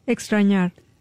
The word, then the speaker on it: extrañar: Extrañar. – Extrañar. – Extrañar, with X. – What does that mean? Extrañar. Am I saying it right or no? – Yes, extrañar.